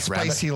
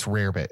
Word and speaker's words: rarebit: It's not spicy rabbit. Like hot spicy. It's rarebit is how you pronounce it. It's not. Rarebit. It looks like rabbit.